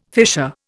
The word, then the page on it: fisher: Indian